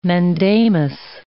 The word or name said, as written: mandamus